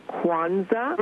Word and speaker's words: Kwanzaa: I'd like to, first of all, tell your guests that it is pronounced Kwanzaa. – Mm-hmm. – It is spelled K-W-A-N-Z-A.